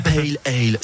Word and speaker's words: pale ale: Euh, alors, si on est français, on va le prononcer pas le à le, mais normalement, il faut dire pale ale.